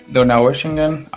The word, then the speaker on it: Donaueschingen: Nada, la ciudad se pronuncia Donaueschingen, aunque todo el mundo le llama Donau.